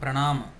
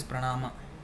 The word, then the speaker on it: pranama: In Sanskrit, if you want to exactly pronounce it in Sanskrit, it is pranama. But it's okay. It's pranam and pranama is the same thing.